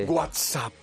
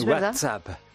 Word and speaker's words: WhatsApp: Qué bien pronuncia esta oyente. – WhatsApp. – ¿Es WhatsApp. – Sí. Fantástico. – Todas las veces lo ha dicho.